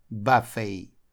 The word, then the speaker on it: buffet: Очень хочется прочитать, как и по-русски, но это слово читается buffet. Очень странно, что буква T в конце не только не читается, а еще и читается звуком I.